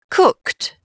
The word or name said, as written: cooked